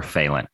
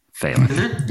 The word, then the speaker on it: Fehlandt: Today, we have on our guest, Oliver Fehlandt. Said it right? Fehlandt.